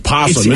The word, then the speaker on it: possum: I thought everyone said you don't say the O. It's just possum.